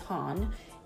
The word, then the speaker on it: Hanh: And that comes from, I cannot pronounce his name, T-H-I-C-H. That's the first name. Then the other part of the name is N-H-A-T. The last name is Han, H-A-N-H.